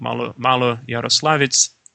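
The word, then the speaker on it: Maloyaroslavets: And so that attempt to stop the French leads to this famous Battle of Malo-Yaroslavets on October 24th.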